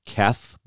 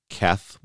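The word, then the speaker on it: Keth (ח): I can't pronounce this letter, but it's Keth, but it's with like that clearing of the throat kind of thing.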